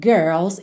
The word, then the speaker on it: girls: E naquela rápida revisão de como pronunciar a palavra girls, especialmente com sotaque mais americano, que tem aquele R enrolado da porta com a perna esquerda.